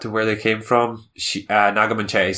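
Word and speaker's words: Naga Munchetty: Naga Manchetti.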